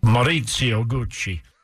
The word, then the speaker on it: Maurizio Gucci: Maurizio Gucci.